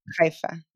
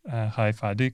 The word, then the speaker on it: Haifa: Is that how you pronounce it? Is it Haifa? – Yeah. It's a lot of spring and saying, so it's Haifa, yeah. – Well, I know very little about Haifa.